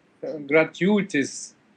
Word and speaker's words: gratuitous: How do you say that word? Gravitigious? Like gravity? Gravitigious? – Gratuitous. – Gratuitous. – Yes, gratuitous.